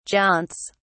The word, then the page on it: jaunts: British English